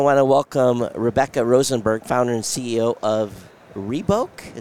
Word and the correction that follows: Reboka: Is that the correct way to say it? – Reboka. Yes.